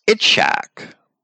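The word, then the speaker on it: Itshak (יצחק): The Hebrew name for Isaac is the same word preceded by a yod, which we would have to pronounce Itshak, which literally means he laughs.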